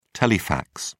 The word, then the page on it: telefax: British English